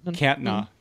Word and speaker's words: Kärntner: Mit Österreicher, Bayern, Sachsen, Österreicher gibt's noch gar nicht, Carinther oder so, aber doch, ja. – Kärntner. Genau.